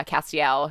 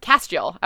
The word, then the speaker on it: Castiel: And then, of course, Castiel, whose name you got right. I, having only seen it typed out, thought it was, I pronounced it Castiel.